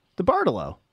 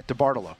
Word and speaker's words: DeBartolo: DeBartolo, I can't say his name, DeBartolo Sr., who was the owner of the 49ers. – Yeah, DeBartolo. – Yeah, DeBartolo, that's it.